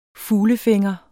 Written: fuglefænger

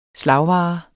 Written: slagvare

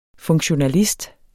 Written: funktionalist

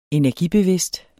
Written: energibevidst

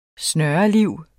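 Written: snørliv

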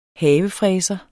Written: havefræser

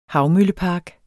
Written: havmøllepark

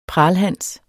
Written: pralhans

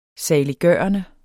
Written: saliggørende

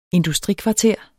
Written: industrikvarter

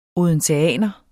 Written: odenseaner

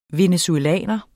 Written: venezuelaner

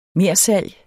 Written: mersalg

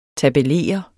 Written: tabellere

